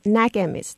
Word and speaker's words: Nägemist: Nägemist, I think, if Annalie agrees with my pronunciation. – Yes, you're very close. Nägemist. It's just Nägemist. That A with the two dots.